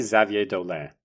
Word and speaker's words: Xavier Dolan: Well, I'm going to say Xavier Dolan. How do you say it? – I say Xavier Dolan.